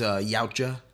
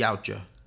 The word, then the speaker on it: Yautja: The Predator is actually, the name, you know, the name of the alien is, Yautja. – Is that, I've seen it like spelled, but I've never heard it pronounced. – It's Yautja.